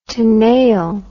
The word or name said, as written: tenaille